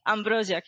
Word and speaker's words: Ambrosiak: I'm Polish, so it's my surname. It's not easy, you know, especially after 5 years in Australia to pronounce from people from here. But yeah, Ambrosiak, it's my surname. That's correct.